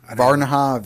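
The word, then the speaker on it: Varginha: Virginia. – Varanha. Varanha. It's you know, it's pronounced in like a Portuguese.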